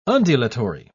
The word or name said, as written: undulatory